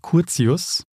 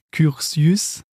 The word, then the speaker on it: Curtius: Einen anderen Namen allerdings, den denke ich, hätte ich anders aussprechen sollen, nämlich ihren Ziehvater, den Philipp Curtius oder, wie ich gesagt habe, Curtius. Eigentlich aber dann, wenn man das so sagen würde, auf Französisch dann eigentlich Curtius und nicht Curtius.